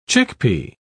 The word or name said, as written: chickpea